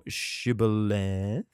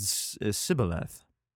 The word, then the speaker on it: Shibboleth: If he said, Nay, then they said unto him, Say, now, Shibboleth. Okay. Say, now, Shibboleth. And he said, Sibboleth. Ooh. For he could not frame to pronounce it right.